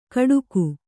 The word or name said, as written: ಕಡುಗು